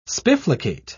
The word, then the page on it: spifflicate: British English